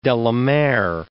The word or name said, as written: de la Mare